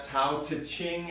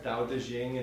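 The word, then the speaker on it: Dao De Jing: The Tao now is spelled D-A-O. It's not Tao Te Ching anymore. It's Tao Te Ching. It's one word, more closer to the Chinese.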